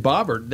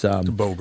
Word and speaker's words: Boebert: Green and Bobbert, they or no, it's not Bobbert. It's Boebert.